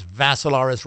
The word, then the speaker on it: Vassilaris: And Dimitri is with three I's, D-I-M-I-T-R-I. The last name is Vassilaris, rhymes with Acidaris, except with you had a V.